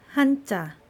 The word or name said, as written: hanja